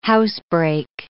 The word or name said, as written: housebreak